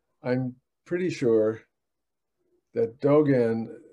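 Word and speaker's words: Dōgen: Dogen's, uh, correct me if I'm pronouncing it wrongly, is it Dogen's Shobogenzo? – Dogen, yeah, Dogen. Dogen's Shobogenzo.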